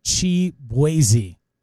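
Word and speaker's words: Chibueze: It's actually spelled goofy. It's spelled Shibuezy. – Yeah, it's like something weird, yeah. – And it's Chibuezy. Chibuezy is the way.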